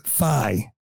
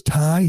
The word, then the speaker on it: Thai: According to a friend, DeSantis would tell dates he liked Thai food, but pronounced it thigh.